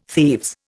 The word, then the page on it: thieves: Indian